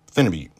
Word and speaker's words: phenibut: And the first one I'd like to talk about, it's people pronounce this a lot of different ways. I call it phenibute. Some people call it phenibut. There's different ways to pronounce it.